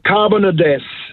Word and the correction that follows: Carbonados: It is C A R B O N A D O S, Carbonados.